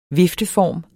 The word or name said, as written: vifteform